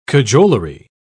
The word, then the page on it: cajolery: British English